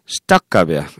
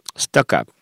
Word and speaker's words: stuck up: 잘난 척 하는 사람을 묘사하는 형용사가 시딱갑이야. 시딱갑. S-T-U-C-K dash up. U-P. 시딱갑.